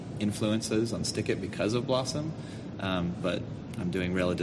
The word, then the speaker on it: Blosxom: Now, it seemed appropriate for Rail to come up with that because he wrote a blogging engine that I can't pronounce, Blossom. – Blossom.